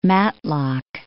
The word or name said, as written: Matlock